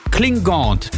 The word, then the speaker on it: Klingande: Das französische DJ-Duo, das ihr jetzt hier gleich vor den Infos um 7 hört, das heißt Klingant. Weil es wird ja Klingande geschrieben und dann französisch ausgesprochen und dann kommt da halt Klingant am Ende raus.